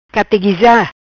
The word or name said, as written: Categuiza